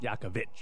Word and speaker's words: Jakovic: Jakovic. On the end. – Could you put an H then? – No. That's how it's spelled.